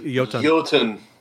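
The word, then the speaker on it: Iotunn: Tower of Cosmic Nihility by Iotun. I don't know if I'm pronouncing this. – It's actually Yotun. – Oh, okay. So there you go, Yotun.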